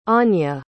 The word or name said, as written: Aanya